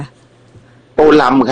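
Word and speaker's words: Tô Lâm: อ่านว่าโตเลิมหรือโตลำคะโตลำครับ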